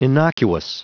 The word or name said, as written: innocuous